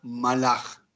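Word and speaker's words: malaj (מלאך): Pues bien, resulta ser que ángel en hebreo se pronuncia malaj de manera singular,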